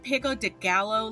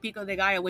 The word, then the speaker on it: pico de gallo: And then calling it pico de gallo, like, at least learn that a 2 L's is pronounced like a Y, you motherfucking assholes.